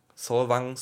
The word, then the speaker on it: Solvang: Solvang, Solvang, da wird, so wird's ausgesprochen.